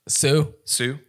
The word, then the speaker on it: Sioux: You had one here, actor Deborah Padgett played an Indian maiden, presumably, I wish I could pronounce the name right, S-I-O-U-X. – Sue? Yeah.